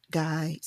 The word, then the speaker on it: Gey: And a man who becomes very important to this story is George Guy, pronounced Guy, spelled G-E-Y.